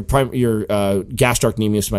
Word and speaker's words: gastrocnemius: So like so your gastrocnemius. Am I pronouncing that right? – Yep, yeah, you got it.